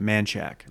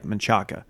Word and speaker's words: Manchaca: William Cannon and they pronounce it Manchac. It looks like Manchaca.